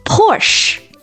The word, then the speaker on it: Porsche: 它读作 Porsche。 – 拼法呢是 P-O-R-S-C-H-E，那这里面这个 C-H 发的是它的吃科是第三个音 Porsche。 – 是一个德语词，所以它的重音在第一个音节 Porsche。